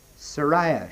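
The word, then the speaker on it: Sarai: Sariah she's called, but we've simply pronounced her Sarah.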